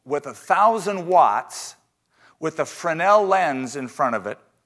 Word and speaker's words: Fresnel: So one scientist by the name of Fresnel, and his name is spelled F-R-E-S-N-E-L,